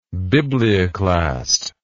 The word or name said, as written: biblioclasm